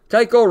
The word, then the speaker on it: Keuchel: And Keuchel rhymes with cycle, so hopefully we do wind up having a couple of Detroit Tigers players wind up hitting for the cycle.